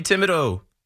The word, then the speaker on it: Thibodeau: And Tommy Tibbs, Tommy Thibodeau, Thibodeau, Kayvon Thibodeaux, Tommy Thibodeau. That's a tough name. It's T H, but it's Tibbs, it's Thibodeau, it's Thibodeau, it's spelled differently with Kayvon and Tommy.